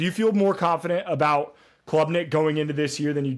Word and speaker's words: Klubnik: And, is it Klubnik or Klubnik? – It's Klubnik.